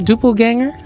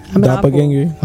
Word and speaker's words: Doppelganger: Am I saying that right? Duplganger? – Doppelganger.